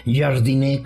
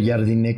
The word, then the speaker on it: Jardine: Pues estaba comprometido estaba comprometido Jardiné, como se le pronuncia Jardiné con el San Luis, estaba toda madre entrenando y todo.